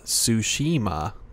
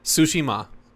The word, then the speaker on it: Tsushima: Of Tsushima. – Tsushima. Can you say that? – Tsushima. – Tsushima. – There it is. And then it's put the emphasis on Tsushima.